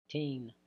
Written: tin

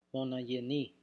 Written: monoyeni

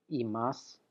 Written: imas

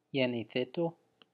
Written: yenithito

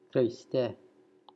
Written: Xriste

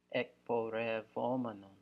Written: ekporevomenon

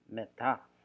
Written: meta

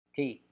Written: Ti

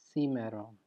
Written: simeron

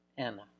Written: ena

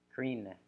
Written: krine

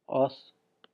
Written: os

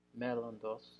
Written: mellondos

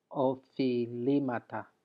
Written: ofilimata

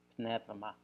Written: pnevma

